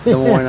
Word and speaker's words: Des Moines: Des Moines, Iowa.